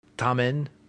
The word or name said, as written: tamen